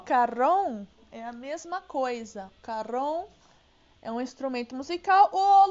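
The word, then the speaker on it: cajón: Sabe por que, que é carrom? Porque é uma palavra em espanhol. E em espanhol, o J tem som de R.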